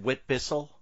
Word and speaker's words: Whit Bissell: Is it Whit Bissell? Is that how you say his name? – Yes. Yes, okay. It is.